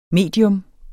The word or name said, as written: medium